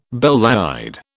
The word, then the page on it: belle laide: British English